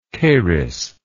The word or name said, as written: Charis